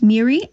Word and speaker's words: Moira: It's spelled M-O-I-R-A, but it's pronounced Miri, and it's Villiard, V-I-L-L-I-A-R-D.